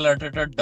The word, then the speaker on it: Arteta: Arsenal coach Arteta? I am not sure if I'm pronouncing it right. – No, Mikel Arteta. Yes.